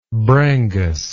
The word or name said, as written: brangus